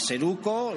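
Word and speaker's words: Zeruko: Seruco, que se escribiría con Z, pero bueno, se pronuncia Seruco, lo vais a ver perfectamente.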